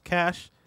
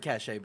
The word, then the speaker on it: cache: Cash is pronounced Cash. – Yeah. – I've heard cachet before. – There are different meanings. Like, are they? – I'm going to cachet some data versus this is a cache of weapons.